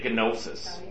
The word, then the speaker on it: gnosis: Well, it probably comes from the Greek because there's a G, and they pronounce it. They say gnosis. We don't say kno.